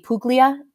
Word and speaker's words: Puglia: We went to, Puglia, P-U-G-L-I-A. I think in Italian fashion, it's actually pronounced Puglia. The G is kind of silent.